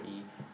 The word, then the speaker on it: eye: Yeah, so in the 18th century, it was optional how you pronounced I. You could pronounce it E-Y-E, or you can pronounce it E or I. And so you pronounce it to make it rhyme.